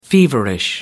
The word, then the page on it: feverish: American English